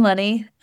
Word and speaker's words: Lunne: You can find me on Instagram at Caroline Lenny. L-U-N-N-E is my last name. Rhymes with honey, kind of complicated one.